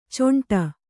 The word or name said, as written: ಚೊಂಟ